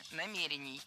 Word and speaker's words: намерений: Намерения – это неверно. Правильно – намерение, намерений. Ударение в этом слове ставим на второй слог и не меняем это ударение при изменении слова. Намерений, намерениях, намерению. Ну а далее мы разберем ударение в слове, с которым не согласна более половины населения России.